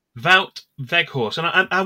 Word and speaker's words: Wout: They're going Woot or Voot, but that's not how you pronounce it.